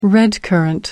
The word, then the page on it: redcurrant: American English